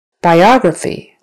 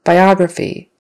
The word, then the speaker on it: biography: Biography. Accent on the second syllable now. Biography.